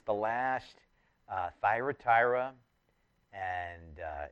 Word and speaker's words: Thyatira: Thyatira, it's actually better pronounced Thyatira, but either way, church at Thyatira remains today in the sense of the people that Jesus is talking about.